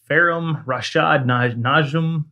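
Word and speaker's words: Faheem Rasheed Najm: The recording artist, whose real name is Farum Rashad Najm, is that how you say it? – Yeah, sounds right.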